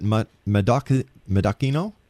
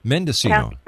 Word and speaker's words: Mendocino: Is it Medocino? – Mendocino.